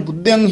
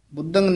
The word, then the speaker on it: बुद्धयं: बुद्धयं ही उच्चारण करना है बुद्धंग नहीं कहना बुद्धयं